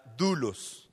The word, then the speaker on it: doulos: Se escribe o la transliteración es doulos, pero se pronuncia dulos.